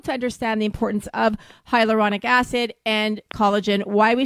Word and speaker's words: hyaluronic: So, Tim, what I'd like to do for people who are listening, obviously you're a collagen expert, can you say it for me? – Hyaluronic. – It took me a long time to know how to pronounce that. – All right, I'm getting hyaluronic. No. – There you go.